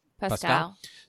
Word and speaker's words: Pascal: Is it Pascal, Pascal or Pascal? – Pascal. – So Pascal.